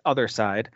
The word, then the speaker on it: Othercide: So it's it's spelled as if it were going to be like patricide or genocide or something like that, but it's it's Othercide.